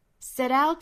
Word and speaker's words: set out: 好，下面我们来看美音的发音，还是来看第一个词哈，就是 set out， set out to correct the world's wrongs. 跟英音是不是有差别的哈？我们看是 set out, set out, set, set, set, set out. 这里也是一个 flap T 的发音了。 Set out, set out, out. 尾部 T 也是省掉了。